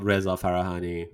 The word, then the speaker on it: Reza Farahani: I'm in LinkedIn, Twitter, all Reza Farahani.